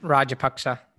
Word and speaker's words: Rajapaksa: Is it Rajapaksa? – Rajapaksa. – Rajapaksa, yeah. And he was, you know, pretty impressive there in the ODI series as well.